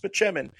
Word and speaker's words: Spaceman: And he's like, it's pronounced Spachemin.